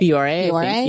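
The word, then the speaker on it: Bioré: Biore.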